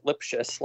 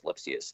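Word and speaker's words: Lipcius: Lipschitz or Lipsius. I know me and you, Chris, we pronounce it Lipschitz, like Dr. Lipschitz from Rugrats, and everyone else, yeah, including Dan Hasty, who's probably the authority, says Lipsius.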